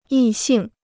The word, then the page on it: 异性: yì xìng